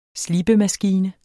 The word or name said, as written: slibemaskine